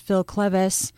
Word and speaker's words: Phil Cleves: Today, I am joined by molecular and cell biologist Phil Cleves.